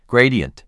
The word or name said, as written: Gradient